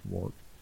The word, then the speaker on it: Ruark: The Old Man and the Boy by Robert Ruark. It's spelled funny. It's R U A R K, like Ruark, but it's pronounced Rourke.